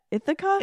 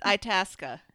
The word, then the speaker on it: Itasca: how do you say that? Ithaca? – It's Itasca. There's an Itasca County and also a Lake Itasca here.